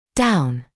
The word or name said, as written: down